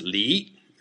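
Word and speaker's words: li (梨): Pears are pronounced li, which rhymes with another character, li, same second tone, but it means to leave, which means separation.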